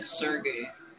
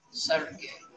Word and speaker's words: Sergey: Do I have to say it with the role? No. – What? – Just say it like Sergey. – Oh, no, you say it with the role. – I don't know how to do that. – Sergey. It's like a slight role, dude.